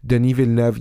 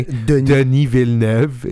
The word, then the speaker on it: Denis Villeneuve: I was expecting like a Denis Villeneuve appearance, because Denis Villeneuve, yes, this is how you say his name, by the way. Denis Villeneuve is how you say his name, because he is from the Quebec, and we are too.